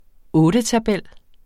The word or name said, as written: ottetabel